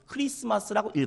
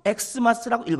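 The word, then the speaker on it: Xmas: 재작년 12월 기독교문화연구소에서 전국에 있는 20세 이상 성인 남녀 500명에게 저 글자를 보여주고 저 글자를 어떻게 읽는지 조사를 했는데 자, 저 글자를 어떻게 읽는 것이 정확한 것일까요? 1번 크리스마스라고 읽어야 된다. 2번 크리스마스 혹은 엑스마스라고 읽어야 된다. 3번 엑스마스라고 읽어야 된다. 정답은? 어떻게 가만히 계셔요? 정답은? 크리스마스라고 읽어야 됩니다. 1번. 만약에 크리스마스 혹은 엑스마스라고 읽거나 그냥 엑스마스 이렇게 읽으면 안 됩니다.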